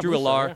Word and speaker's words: Drew Allar: Drew Allar.